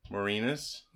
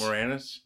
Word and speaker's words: Moranis: Let's hope that he can replace or bring on Rick Moranis. – Moranis? – Moranis. – Moranis? – Oh, is that how you say it? – Moranis.